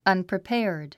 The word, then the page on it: unprepared: ʌ̀npripέərd; アンプリペアード